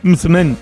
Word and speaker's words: msemen: Es una palabra complicada, decía, es M-S-E-M-E-N. Misemen, misemen, ¿vale? Y se pronuncia como lo ha hecho Hakima.